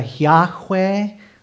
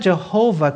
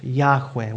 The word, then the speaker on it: Yahweh: It could be uh, Yahweh or Yahweh. It's not Jehovah because there's no J sound in Hebrew, but it's God's personal name.